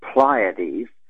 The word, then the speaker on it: Pleiades: So I pronounce it Pleiades, or if you don't like that, just say Seven Sister Star Cluster,